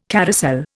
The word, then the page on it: carousel: Indian